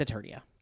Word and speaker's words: Eternia: He-Man lives on Eternia. This man lives in a wonderland. I don't know. It doesn't matter. Anyway, it's Eternia. Learn how to pronounce their names. It's not that hard.